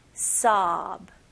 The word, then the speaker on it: sob: 근데 여기서는 입술은 사용하지 않고 그냥 턱만 벌리는 느낌으로 보세요. sob.